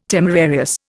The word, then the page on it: temerarious: Indian